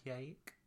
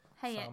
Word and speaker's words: Hayek: Frida, with Salma Hayek. I didn't say that right. – Hayek. – Salma Hayek, yeah.